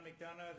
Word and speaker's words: McDonough: My name is John McDonough. That's spelled M-C, capital D-O-N-O-U-G-H, and I'm the town planner.